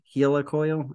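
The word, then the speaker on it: Heli-Coil: Called a Healy coil, by the way, not a Healy coil. – How do you pronounce it? – Healy? – Hela coil. Okay.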